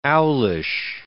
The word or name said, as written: owlish